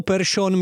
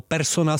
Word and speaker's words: Persson: používali u výslovnosti některých švédských jmen správnou, ale v Česku netradiční variantu s eš. Po ledě tak jezdil třeba Ekman Larsson nebo Persson místo Persona s Ekmanem Larssonem.